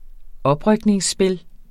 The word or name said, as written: oprykningsspil